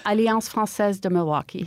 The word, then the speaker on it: Alliance Française de Milwaukee: Now, and you're with the organization, help me with my pronunciation here. – Alliance Française de Milwaukee. – There we go.